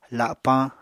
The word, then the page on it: lapañ: apatani